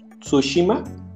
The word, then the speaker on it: Tsushima: Entonces, el primer nominado es Ghost of Tsushima. Eso. Si está bien pronunciado, querido Alf. Es correcto.